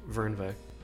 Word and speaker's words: Fernweh: If it's German, it's Fernweh.